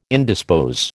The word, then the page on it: indispose: American English